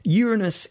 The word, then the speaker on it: Uranus: And in case you think I'm being prudish with the way I say Uranus, I'm not. Uranus is the scientifically recognized pronunciation of this planet. Something to do with it being from Latin.